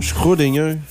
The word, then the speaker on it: Schrödinger: Schrödinger.